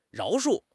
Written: 饶恕

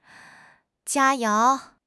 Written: 佳肴

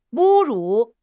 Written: 侮辱

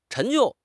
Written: 陈旧

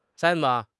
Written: 参谋

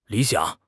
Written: li3 xiang3

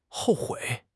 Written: hou4 hui3

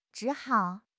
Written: zhi3 hao3

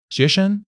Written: xue2 sheng5

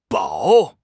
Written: bao3

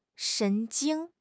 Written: 神经

